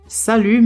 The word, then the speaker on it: salut: Salut. Salut. Salut se escreve com S, A, L, U, T. Mas quando a gente fala, a gente não fala o T. A gente fala apenas salut.